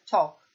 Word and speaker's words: talk: Walk, ecco, walk si pronuncia così, senza L, cioè quella L non esiste, così come per talk, che vuol dire parlare. Walk, camminare, talk, parlare, tutti e due hanno una sequenza LK pronunciando la quale si omette completamente il suono della L.